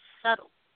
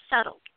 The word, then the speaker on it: subtle: and she pronounces, in real life, the word subtle. Told her, no, it's pronounced subtle. When have you ever heard somebody say you're being very subtle? It's subtle.